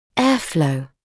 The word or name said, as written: airflow